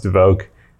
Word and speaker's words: Dvorak: It's spelled Dvorak, but I think it's pronounced Dvorak.